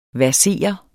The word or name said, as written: versere